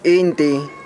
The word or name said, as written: Inti